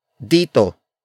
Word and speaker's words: dito: That is explained by the next word, dito. Dito. Dito. Dito is spelled as D-I-T-O.